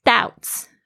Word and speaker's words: doubts: OK，这个是一个潜规则，一定要注意它发音的这个组合， bt 的 b 就是不发音， doubts 就是只有发 TS 这个声音。 Are thieves，这边要注意一下 thieves 也是比较难念的， T-H-I-E-C 这个 TH 的声音是 th, 稍微咬轻轻的咬住舌尖然后突出的气音 th, th, Eves，然后 V 也比较难发，它是要轻轻的咬上嘴唇，哦，不要念成我的声音 Thieves OK。